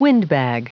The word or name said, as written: windbag